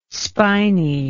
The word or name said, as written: spiny